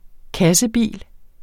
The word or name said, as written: kassebil